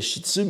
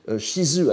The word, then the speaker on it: shizu: L'ancienne prononciation était shitsu, mais il est prononcé shizu à l'époque de Heian, donc à l'époque de Jien.